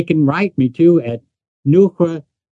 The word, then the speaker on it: Noohra: It's N as in Nick, O-O-H-R-A, Neura, and it means enlightenment.